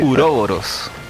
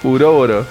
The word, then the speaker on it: Uroboros: Y se pronuncia en español que Uroboros. – Uroboros.